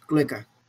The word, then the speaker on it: cloaca: Esa es la base de la palabra clueca, cuando una gallina está clueca, que es la pronunciación de cloaca en inglés, clueca.